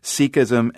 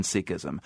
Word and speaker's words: Sikhism: Before I go any further, I know there are two pronunciations of the faith, Sikhism and Sikhism. And for the purposes of this conversation, we're going with Sikh and Sikhism.